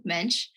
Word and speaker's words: Moench: Mench, silent O. It's kind of a curveball. – Ah, okay. Thank you, Mench,